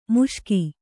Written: ಮುಷ್ಕ

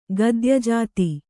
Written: ಗದ್ಯಜಾತಿ

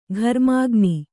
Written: ಘರ್ಮಕಣ

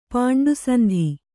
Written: ಪಾಂಡುಸಂಧಿ

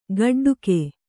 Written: ಗೋಟಡಕೆ

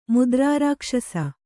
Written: ಮುದ್ರಾರಾಕ್ಷಸ

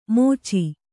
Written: ಮೋಚಿ

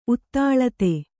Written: ಉತ್ತಾಳತೆ

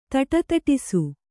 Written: ಧಡಧಡಿಸು

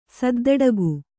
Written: ಸದ್ದಡಗು

